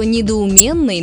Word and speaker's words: недоуменный: Например, знаете ли вы, что «недоуменный» нужно говорить только так, через «е», а вовсе не через «ё», как многие любят.